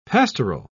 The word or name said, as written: pastoral